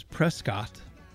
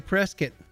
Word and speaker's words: Prescott: Apparently I said it wrong by pronouncing it the way that it's spelled, which is Prescott, which sounds rather proper. But no, we're going to say Prescott, like it's a biscuit or something.